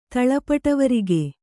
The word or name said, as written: ತಳಪಟವರಿಗೆ